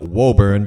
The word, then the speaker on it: Woburn: Yeah, it's spelt Woburn, but pronounced Woburn, go figure.